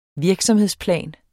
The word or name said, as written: virksomhedsplan